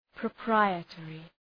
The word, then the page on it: proprietary: prə’praıə,terı